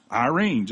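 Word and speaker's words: Irene (εἰρήνη): It's pronounced Irene, just like the lady's name.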